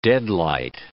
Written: deadlight

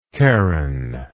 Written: charonic

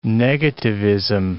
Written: negativism

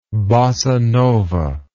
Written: bossanova

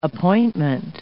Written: appointment

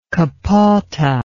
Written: kapote

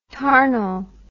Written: tarnal

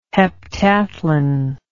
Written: heptathlon

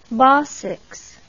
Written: bosox

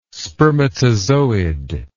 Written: spermatozoid

